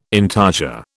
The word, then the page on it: intarsia: British English